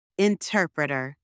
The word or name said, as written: interpreter